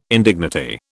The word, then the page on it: indignity: British English